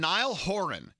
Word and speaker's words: Niall Horan: P-I-N-K. 30 Seconds to Mars. And Niall Horan. Here it goes. It says Nile as in dial, Horan, whore as in horse, Horan, emphasis on whore.